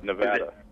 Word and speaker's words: Nevada: Over there in that state there that you're in, what's the correct way to pronounce it? – Nevada. – Nevada? Because a lot of people say Nevada. Now, that's wrong, right? – Because if you're from Nevada, you say Nevada.